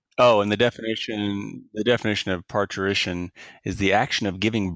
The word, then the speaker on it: parturition: And I think, and the pronunciation, if I'm recalling how this works, is almost like a ch in there, so parturition.